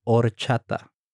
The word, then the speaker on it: horchata: In this case, la before horchata. Okay. And also remember that in Spanish, the H is silent. – So oh. it, it should be horchata.